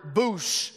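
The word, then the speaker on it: boosh (בּוֹשׁ): This Hebrew word ashamed is the word boosh is simply how you say it.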